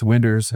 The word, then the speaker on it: Winders: Winders is the name of this main character, not Winders. It's Winders. It rhymes with cinders.